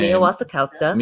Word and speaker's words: Wasikowska: Mia Wasikowska.